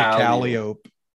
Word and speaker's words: Calliope: So Calliope, right? Calliope. That's Calliope. – Okay. – We pronounce it Calliope.